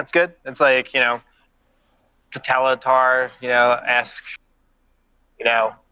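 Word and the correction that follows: Totalitär: Did you say Totalitar? – Is that how you say it? – Well, you know that band? – That's a pretty popular band, right? – Huh. Yeah. – Totalitar.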